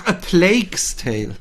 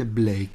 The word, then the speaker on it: A Plague's Tale: Ach, A Plague's Tale. – Habe ich es jetzt falsch ausgesprochen, oder was? – Ich habe Plague's Tale verstanden. Und es ist ja eine Plague, nicht eine Blake.